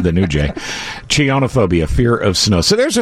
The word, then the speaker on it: Chionophobia: Chinophobia, fear of snow. – Oh, I thought you were going to say chins. – Oh, I'm sorry. I pronounced it wrong. Chionophobia.